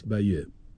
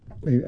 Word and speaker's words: Bayeux: Se escribe así, Bayeux, Bayeux. – con Y. Bayeux. Bayeux.